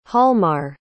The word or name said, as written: hjalmar